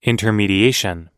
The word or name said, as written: intermediation